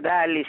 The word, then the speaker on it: Dalli: penso Dalli si pronunci, non da lì perché perché non è francese, eh, lì a Malta eh, c'è il maltese o comunque l'inglese, quindi non credo che sia da lì.